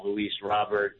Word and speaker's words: Luis Robert: I've uh, so I've always heard it pronounced as uh, Luis Robert.